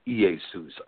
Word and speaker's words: Iesous: I-E-S-U-S. – Yes. – And in the 1611 KJV, it's Iesus, I-E-S-U-S. And that is actually agrees with the Greek text, which is I-E-S-O-U-S, which would be pronounced Iesus.